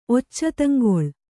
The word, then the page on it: ಒಚ್ಚತಂಗೊಳ್: occata goḷ